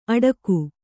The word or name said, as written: ಅಡಗು